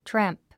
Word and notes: tramp: trǽmp; トラァンプ

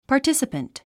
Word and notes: participant: pɑː r tísəpənt; パーティスィパ ント

